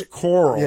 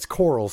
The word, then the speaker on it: Corll: Yeah, and his name is so weird, too, with the C-O-R-L-L. So it's Coral. – Yeah, it's Coral. C-O-R-L-L.